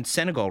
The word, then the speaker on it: Senegal: Is that how you pronounce it? – Yeah, Senegal. That's right.